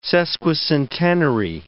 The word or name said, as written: sesquicentenary